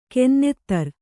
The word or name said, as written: ಕಾನೂನುದಾರ